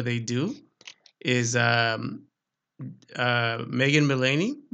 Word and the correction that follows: Mullally: Megan. Hang on, let me make sure I said her name. Say her name right. Mullally, right? Mullally.